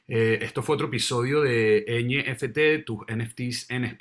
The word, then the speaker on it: NFT: No, gracias a ustedes por invitarme y bueno, emocionada de ver a muchas más personas uniéndose a NFT, así es como lo pronuncian, ¿no? – Así Genial. – mismo, NFT, NFT en español.